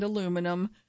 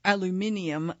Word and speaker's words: aluminium: But he did so as he explained that this component and that component are made of magnesium and carbon fiber and aluminum. He pronounced it aluminium, not aluminum, which I respected as a Brit myself, and he noted that these are the lightest materials on Earth, and that there is no more diminutive alternative.